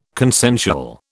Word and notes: consensual: British English

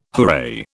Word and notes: hooray: British English

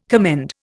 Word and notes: commend: Indian

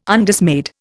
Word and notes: Undismayed: Indian

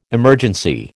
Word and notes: emergency: American English

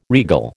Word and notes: regal: American English

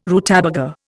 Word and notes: rutabaga: Indian

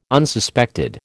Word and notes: Unsuspected: American English

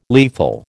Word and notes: lethal: American English

